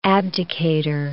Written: abdicator

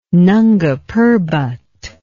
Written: Nanga Parbat

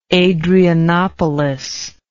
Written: adrianopolis

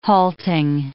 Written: halting

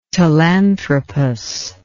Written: Telanthropus

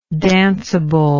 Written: danceability

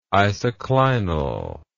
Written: isoclinal

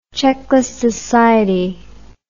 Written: checkless society

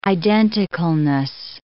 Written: identicalness

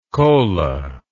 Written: kola

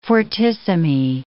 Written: fortissimi

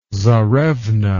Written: tzarevna

